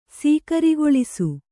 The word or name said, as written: ಸೀಕರಿಗೊಳಿಸು